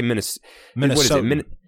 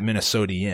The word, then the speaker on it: Minnesotan: How do you say Minnesotan? – Minnesotan? – Yeah, not Minnesotan. No I-A-N. That's not Minnesotian.